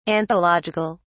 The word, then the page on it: anthological: British English